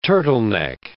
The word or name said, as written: turtleneck